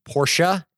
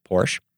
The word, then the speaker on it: Porsche: And for clarifying for me that Portia is pronounced Portia and not just Porsche.